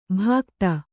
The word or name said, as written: ಭಾಕ್ತ